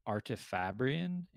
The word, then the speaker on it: Artifabrian: She's described as an Artifabrian. Am I saying that right? – Yes.